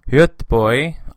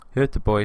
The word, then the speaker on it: Göteborg: We stayed in Göteborg, i.e. Gothenburg. Göteborg is what they pronounce it in Swedish.